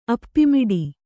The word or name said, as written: ಅಪ್ಪಿಮಿಡಿ